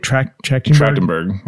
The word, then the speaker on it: Trachtenberg: How do you say it? Trachtenberg. – Yep.